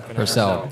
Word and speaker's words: Purcell: We have Anthony, is it Purcell? – Purcell, yeah. – Purcell.